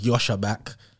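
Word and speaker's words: Joscha Bach: I was watching his podcast with some guy called, it's pronounced Yosha Back.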